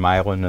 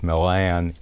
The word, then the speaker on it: Milan: And this was in a little town called Milan, Ohio. And Milan is spelled like Milan, Italy, but it's called Milan.